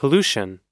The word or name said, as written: pollution